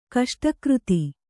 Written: ಕಷ್ಟಕೃತಿ

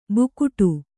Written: ಬುಗುಡ

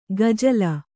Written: ಗಜಲ

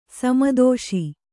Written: ಸಮ್ಮತಿಸು

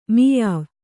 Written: ಮುಯ್ವು

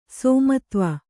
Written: ಸೋಮತ್ವ

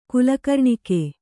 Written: ಕುಲಕರ್ಣಿಕೆ

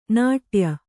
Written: ನಡ್ಯೆ

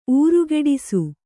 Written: ಊರುಗೆಡಿಸು

